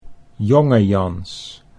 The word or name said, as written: Jongejans